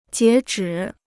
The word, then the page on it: 截止: jié zhǐ